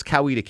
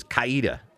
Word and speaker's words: Coweta: But it's Coweta County, because Mark McHale says Coweta.